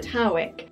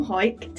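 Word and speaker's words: Hawick: Now, some people pronounce it Howick, but a quick Google has taught me that people from Hoik tend to call it Hoik.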